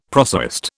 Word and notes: prosaist: British English

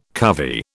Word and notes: covey: British English

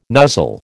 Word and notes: nuzzle: American English

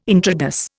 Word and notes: intrados: Indian

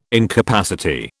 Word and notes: incapacity: British English

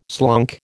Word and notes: slunk: American English

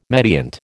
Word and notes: mediant: American English

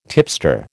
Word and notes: tipster: American English

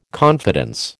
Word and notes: confidence: American English

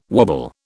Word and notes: wobble: British English

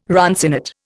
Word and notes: runcinate: Indian

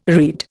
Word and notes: read: Indian

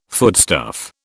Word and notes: foodstuff: British English

